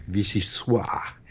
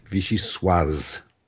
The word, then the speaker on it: vichyssoise: Okay, waiters in restaurants offering this potato leek cream soup often mispronounce it as vichyssoise in a mistaken attempt to sound authentically French. Setting aside the fact that this soup was invented in New York, not France, French final consonants are not silent when they are followed by an E. The correct pronunciation is vichyssoise.